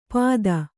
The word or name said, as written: ಪಾದ